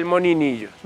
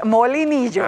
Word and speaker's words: molinillo: Y entonces, porque hay gente que le dice el moninillo. – No, es molinillo.